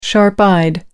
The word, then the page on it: sharp-eyed: American English